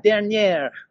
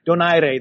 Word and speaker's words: Donaire: However, Rayflo came from out of left field with Donaire, which was some kind of, I don't know, French inspired, like, Dernier, Donaire. I don't know what the hell that was. Leave that name alone is all I have to say. It's Donaire.